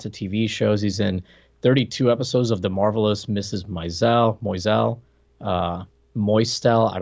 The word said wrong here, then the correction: Maisel: I thought it was Maisel, but what do I know? – Sure, that makes more sense the way it's spelled.